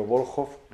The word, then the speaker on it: Volkhov: Luego me preocupaba mucho, y por eso fui a Rusia, documentar bien las posiciones en las que está el personaje del río Volkhov, los rusos lo pronuncian así,